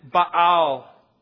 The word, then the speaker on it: Baal: And here we have the prophets of Baal, or as they would have pronounced it, Baal.